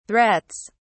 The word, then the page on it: threats: British English